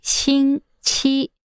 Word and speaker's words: 星期: In pinyin, this is spelled X-I-N-G, with the X being pronounced as xi. – How would you say week? – 星期. – That's right, 星期.